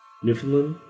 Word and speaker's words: Newfoundland: I believe it's pronounced Newfoundland. Newfoundland.